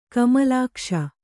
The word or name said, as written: ಕಮಲಾಕ್ಷಿ